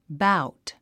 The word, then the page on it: bout: báut; バァウト